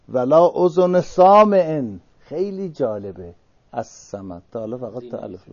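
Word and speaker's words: الصمد: همونطوری که السمد لامش تلفظ نمیشه، به گوش کسی هم نمیاد، درک به حواس نمیشه و کسی نمیشنوه.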